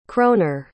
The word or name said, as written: kroner